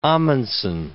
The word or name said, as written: Amundsen